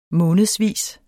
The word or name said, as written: månedsvis